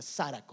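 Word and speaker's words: Sara (χαρά): Pero la palabra griega que se escribe en la Biblia para alegría es una palabra que se pronuncia Sara con, con X. Sara.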